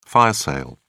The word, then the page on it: fire sale: British English